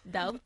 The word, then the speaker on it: Doubt: No The Bout, The Bout, ¿cómo se dice, Doubt. – Eso, Doubt, eso.